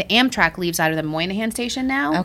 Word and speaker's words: Moynihan: Have you been to the new Monahan Station? I don't know how to pronounce it. Moynihan.